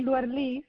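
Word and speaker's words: Luerlís: y me puso mi Luer Lis, y lleva un acento en la I, lo que pasa es que Ah, sí, es Luerlis entonces.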